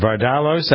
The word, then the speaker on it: Vardalos: How do you pronounce her name? Vardalos, I think it is. – That's correct. Yeah.